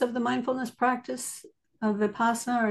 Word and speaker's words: Vipassana: Vipassana? Mm-hmm. Is that the right way to say it? – Vipassana.